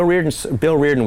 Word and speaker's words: Riordan: Bill Reardon was Jimmy Connors' manager. – Oh, right. It's spelled like Riordan. – Yes. – R-I-O-R-D-A-N, right? – Yes, and yeah.